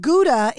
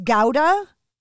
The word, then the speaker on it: Gouda: So I'm saying it wrong. It's not Gouda. It's Gouda. – Gouda.